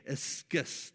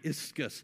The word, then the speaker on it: ischus: Okay, ischus. This is what it's pronounced, ischus in the Greek.